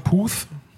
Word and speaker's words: Puth: Hymne gesungen hat Charlie Puth oder Putt, wie wird er ausgesprochen? Puth.